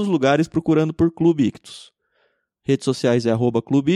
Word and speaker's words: Ichthus: Só lembrando que Ictus se escreve I-C-H-T-H-U-S.